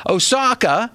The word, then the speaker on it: Osaka: Osaka, is that how you say it? – Osaka.